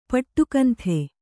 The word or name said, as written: ಪಟ್ಟುಕಂಥೆ